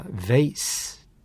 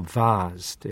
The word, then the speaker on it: vase: 大好きなヴェイスと言いましたか。イギリス式に言うとヴァーズという発音になりますが、花瓶のことですよね。